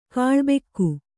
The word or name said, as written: ಕೂಳಬಾಕ